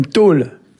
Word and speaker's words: taol: Alors, ça s'écrit T-A-O-L, mais bien sûr, quand c'est la table, N-D-O-L, il y a une mutation derrière l'article.